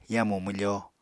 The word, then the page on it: yamu mwfo: Apatani